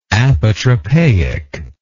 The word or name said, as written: apotropaism